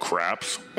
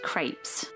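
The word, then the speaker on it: crepe: Hey, I'm gonna want to order four of these all-meat craps. – They're pronounced crepes. – Not where I come from.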